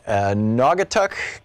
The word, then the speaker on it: Naugatuck: David, in Naugatuck, Connecticut.